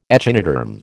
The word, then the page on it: echinoderm: American English